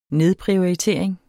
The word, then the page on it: nedprioritering: ˈneðpʁiɒiˌteˀɐ̯eŋ; ˈneðpʁioɐ̯iˌteˀɐ̯eŋ